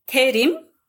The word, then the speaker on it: Tayreem: It's Tayreem, but it's spelled with R and not L, so it's kind of gets quite confusing.